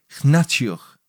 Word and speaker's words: Hnatiuk: The reason lies solely in my poor pronunciation of her surname, Hnatiuk. It's H-N-A-T-I-U-K.